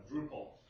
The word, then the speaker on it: Drupal: correctly pronounce Orkut or Drupal. I always called it Drupal.